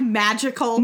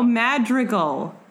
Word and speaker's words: Madrigal: How do you say it? – Madrigal. – Like magical? – No, Madrigal.